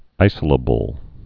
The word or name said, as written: isolatable